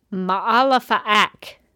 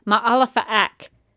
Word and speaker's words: Malefic: On this series, Malefic, which I believe, if I made my notes correctly, pronounced on the show as Maalefaak. So that's a different pronunciation. – Yeah, it is. – So you have Malefic, and then you have Maalefaak, which I think is actually a way more fun way to pronounce it.